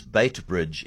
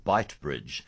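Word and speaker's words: Beitbridge: Jessica says, one pronunciation that drives me insane is bait bridge instead of bite bridge.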